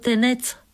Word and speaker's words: Тенец: Докато ние с селянина ловяхме ярабици, тенецът седнал и написал на машината разказ за тенеца. Позволявам си да го поднеса на читателя тъй, както е написан от самия него, заедно с молбата да произнасяме правилно името му, като поставяме ударението на последната сричка.